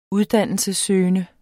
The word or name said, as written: uddannelsessøgende